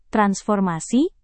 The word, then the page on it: transformasi: Indonesian